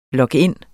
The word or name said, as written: log-in